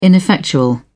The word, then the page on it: ineffectual: British English